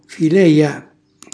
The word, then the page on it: focaloru: calabrese